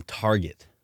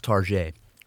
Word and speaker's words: Target: I got this baby from Target. – It's pronounced Target. It's French.